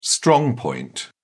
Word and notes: strong point: British English

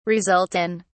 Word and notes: result in: British English

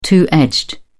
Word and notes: two edged: British English

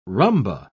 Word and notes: rhumba: British English

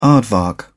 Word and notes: aardvark: British English

